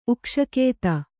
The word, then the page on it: ಉಕ್ಷಕೇತ: kannada